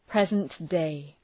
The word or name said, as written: present day